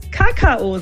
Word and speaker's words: Kakao: Man kann es sich vielleicht so merken, dass ich ans Kauen denke, ja, Kakao, und dann eben auf die zweite Silbe betone. Aber, und jetzt kommt eben das Schöne, es darf anscheinend auch jeder Kakao sagen. Und hier ist jetzt die Betonung auf der ersten Silbe.